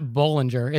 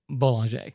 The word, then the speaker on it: Bollinger: And it's not Bollinger, it's Boulanger.